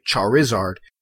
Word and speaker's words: Charizard: And the I is not silent. You do pronounce the I Charizard. You don't say Charizard.